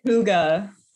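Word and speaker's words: hygge: How do you say Huga? – Gosh, I was gonna say hi. – I was literally nowhere near that.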